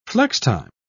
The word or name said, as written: flextime